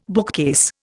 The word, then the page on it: bookcase: Indian